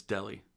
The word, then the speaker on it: Dele: I said his name right, didn't I? – No, it's Deli. – Deli? – Ali Deli.